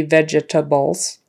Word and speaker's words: vegetables: Again, it's just three syllables, even though you might think it should be vegetables. It's not. We just say it, vegetables, vegetables. Vegetables, vegetables. It's like that E is gone.